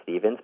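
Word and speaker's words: Stephens: Hi, my name is John Stevens. It's spelled with a PH, but it's actually pronounced Stevens. My full name is John Robert Stevens.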